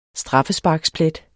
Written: straffesparksplet

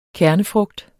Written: kernefrugt

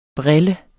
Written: brille

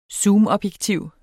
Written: zoomobjektiv

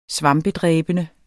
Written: svampedræbende

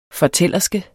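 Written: fortællerske

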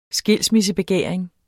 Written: skilsmissebegæring

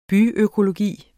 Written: byøkologi

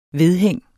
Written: vedhæng

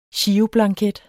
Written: GIROBLANKET

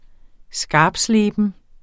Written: skarptsleben

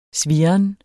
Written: svirren

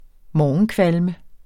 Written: morgenkvalme

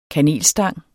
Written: kanelstang

